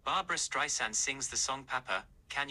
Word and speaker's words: Streisand: My name isn't with a Z. It's Streisand, like sand on the beach. Now, how simple can you get? Sand on the beach, Streisand. So anyway, you know, I decided, how do I change this?